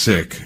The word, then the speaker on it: Sikh: Now, I've always pronounced it Sikh, and by the way, my pronunciation of your name, as well as the religion Sikh, is something that I want to talk about next, but it's pronounced Sikh. – Yes. – Okay, not Sikh.